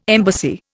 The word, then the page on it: embassy: Indian